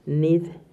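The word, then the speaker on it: Nith-Haiah: Y se escribe N-I-T-H, H-A-I, latina, A-H. Nid se pronuncia.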